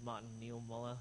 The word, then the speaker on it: Martin Niemöller: There's that famous quote that was quoted by that German minister, Stefan, I don't know how to pronounce it. Is it Martin Niemöller on the right? Right, cool. I'm saying it right.